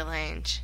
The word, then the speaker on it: Lange: Bette Midler and Jessica Lange. – Yes! And Slang. We've been pronouncing it wrong the whole time. – Slang? – It is, yes, Lang. – It's always been Lang.